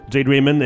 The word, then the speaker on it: Jade Raymond: Jeanne Raymond. – Jade Raymond, exactement.